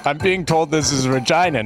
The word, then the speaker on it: Regina: The fact that Regina rhymes with vagina is something that's like...